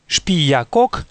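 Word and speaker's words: Xpiyacoc: Говоря о сотворении мира, книга Пополь Вух называет прародителей мира именами Шпиякок и Шмукане. Отметим для дальнейшего, что начальная буква «Х» в определенных словах киче и в начале собственных имен произносится как «ше».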